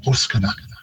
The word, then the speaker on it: Wascana: Again, that's another one where Wascana, we've got a very English stress pattern on it, putting it on the second syllable, whereas in Cree it would normally be on the first, a little more emphasis, a higher pitch, and Wascana for bones.